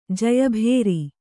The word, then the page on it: ಜಯಭೇರಿ: jayabhēri